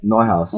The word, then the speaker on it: Neuhaus: Neuhaus.